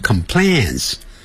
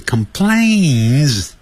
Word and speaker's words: complains: 哎，这个词可不要念成 complains，发 a 音 complains，就像我小妹的名字叫 Jane，不要念成 Jane。